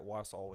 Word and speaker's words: Wausau: I know up in that area where you grew up, between the Freeport, Illinois, Wausau, did I say that right? Wausau, Wisconsin.